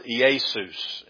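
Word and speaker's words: Iesus (Ἰησοῦς): First of all, the name Jesus comes from the Greek word, and bear with me here. It's pronounced Iesus is what the word Jesus comes from in the Greek.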